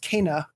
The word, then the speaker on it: Kena: And it's also one of those things, now that you bring it up, there was a Game Informer cover story for Kena, which actually is pronounced Kena, according to them.